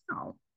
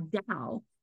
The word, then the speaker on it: Dao: I've been saying Tao, not Dao. And I even before we started this podcast, I was thinking, I think it's Dao, but it's spelled with a T.